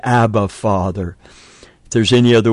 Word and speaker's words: Abba: This word Abba in the Aramaic is akin to our word that infants form when they first recognize their father, Dada. It's just two syllables, and in the Aramaic it would be Abba, Abba, Abba.